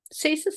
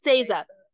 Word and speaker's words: Saysa: S A Y S A. – Yeah, so I think it's Sasa. Sasa, yeah.